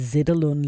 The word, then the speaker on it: Zetterlund: Og her kommer hun til at lave en koncert, som hedder Tribute to Monica Zetterlund. Det er den 6. juli. Og jeg tror lige, jeg kom til at udtale Zetterlund lidt forkert. Det er Zetterlund, Monica Zetterlund, Tribute to Monica Zetterlund.